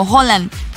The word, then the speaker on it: Haaland: O sea, los tiempos extras, y que lamentablemente pues no nos pudo vengar el Haaland, porque no es Haaland. Estaba investigando y la doble A se pronuncia como Haaland, no Haaland.